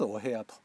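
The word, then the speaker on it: 汚部屋: 汚い部屋と書いてお部屋って読ませるみたいですけど、脱お部屋と、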